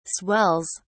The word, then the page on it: swells: British English